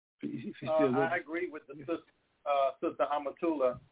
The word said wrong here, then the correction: Amatullah: Did I say that right? – Amatullah. That's the way Imam Muhammad told me to pronounce it. Amatullah.